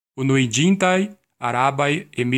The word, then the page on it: Unuiĝintaj Arabaj Emirlandoj: /u.nu.iˈdʒin.tai̯ aˈra.bai̯ ˌe.mirˈlan.doi̯/